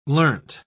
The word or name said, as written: learnt